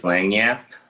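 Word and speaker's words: lagniappe: Yeah, for the I'm gonna say this wrong. Lagniap. – I would say like maybe it's Langyap. Langyap. Yeah, all right. Yeah, that, that sounds better than what I said.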